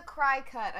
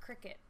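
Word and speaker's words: Cricut: Okay, what is the cry cut? I'm sorry. I'm sorry to change the subject real quick from you, Justin, but what is this cry cut? – It's pronounced cricket.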